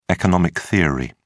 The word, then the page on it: economic theory: British English